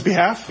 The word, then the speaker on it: behalf: What is behalf? – Behalf, that's where the silent L comes in.